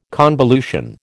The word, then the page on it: convolution: American English